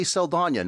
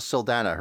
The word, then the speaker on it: Saldana: So, Zoe Saldana, apparently Saldana, not Saldana. Her real name is apparently with the accent on the end.